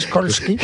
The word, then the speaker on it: Skolsky: No, señor, no es SH, es SKOLSKY.